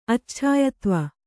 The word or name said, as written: ಅಚ್ಛಾಯತ್ವ